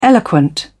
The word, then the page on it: eloquent: British English